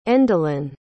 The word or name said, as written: endelyn